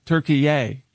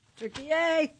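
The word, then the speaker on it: Türkiye: So he immediately demanded that it be pronounced Turkey-ay. Turkey-ay. Turkey-ay.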